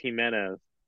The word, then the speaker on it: Giménez: You have Andrés Giménez. That's how you say it, right? – I think it's Giménez.